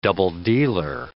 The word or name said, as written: double-dealer